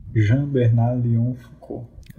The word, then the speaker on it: Jean Bernard Léon Foucault: Jean Bernard Léon Foucault.